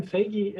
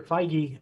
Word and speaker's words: Feige: Kevin Feige, or Feige, I said his name wrong, I know.